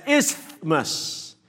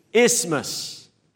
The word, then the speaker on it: isthmus: Many people pronounce it as isthmus, trying desperately to fit the TH inside. But actually, the proper way to pronounce it is without the TH. It's silent. So simply, isthmus.